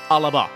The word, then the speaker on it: Alba: And for all you Sassenachs out there, it's pronounced ALBA, as in, all about me.